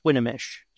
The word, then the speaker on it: Swinomish: And did you immediately go from them to the, I call it Swinomish, but I heard you just pronounce this a different way. – Yes, Swinomish. So the O in there is actually pronounced like an A.